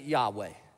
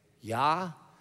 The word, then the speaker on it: Yahweh: It's just four letters, and it's in English, we pronounce it Yahweh, Yahweh. And in Hebrew, when you say it, it's supposed to sound like inhaling and exhaling. Yahweh.